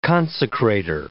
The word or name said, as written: consecrator